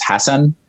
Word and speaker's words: Hassan: Well, I my first language was Arabic, so I would probably say the colloquial way, which is Hassan, but I would never expect anybody to be able to say that from the West.